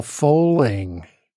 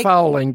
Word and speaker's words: fowling: was a thing called Fowling. Have you ever heard of Fowling? It's spelled F-O-W-L-I-N-G. – Is it? – Not like, fouling, but fowling.